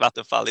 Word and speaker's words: Vattenfall: Vanderfall battery power as a service, Vattenfall. Yes. I'm probably pronouncing Vattenfall, exact Vattenfall.